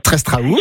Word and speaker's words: Trestraou: Sur la plage du Triestran. – Trestraou. – Je prononce bien.